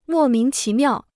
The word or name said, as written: 莫名其妙